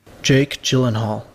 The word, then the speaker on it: Gyllenhaal: Here's the actual pronunciation according to Pronunciation Book on YouTube. – Jake Gyllenhaal. – Yes.